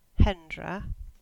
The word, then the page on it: Hendra: English